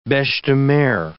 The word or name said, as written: beche-de-mer